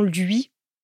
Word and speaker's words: lui: Alors, comment tu pourrais, quel conseil on pourrait donner à nos amis pour qu'ils le prononcent correctement euh, bah, Peut-être penser au pronom lui, qui connaissent sans doute déjà lui ou de l'huile. – Oui, mhm, tout à fait. Donc, lui, Louis, Louis.